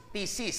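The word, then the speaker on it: ktisis: Sabi, ang ibig sabihin ng new creation is thesis, silent K, K-T-I-S-I-S, thesis in Greek, which means recreated.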